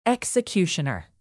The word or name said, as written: executioner